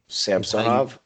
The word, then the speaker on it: Samsonov: It's actually Samsonov, I think. – Samsonov? – I think you pronounce it Samsonov. Accent okay. On the so.